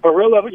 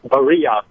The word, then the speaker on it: Barilla: Hey, that Barilla you're talking about, it's actually it's pronounced Barilla.